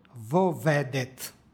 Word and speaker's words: vovedet: Između početnih suglasnika dodaje se izgovorno o radi prenošenja preciznijeg značenja. Nešto slično imamo i u molitvi gospodnjoj. I ne vovedi nas vo iskušenije. Dakle, izgovor je vovedet.